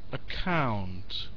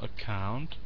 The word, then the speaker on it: account: Account. So the accent on the second syllable. Account.